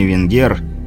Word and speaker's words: Венгер: Старший блокпоста с подковообразной бородой по имени Венгер, с ударением на последний слог, по рации зевая вызвал подмогу с южного блокпоста.